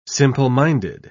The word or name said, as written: simple-minded